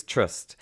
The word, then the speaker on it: TR/ST: And the band is Trust, which is spelled TR/ST.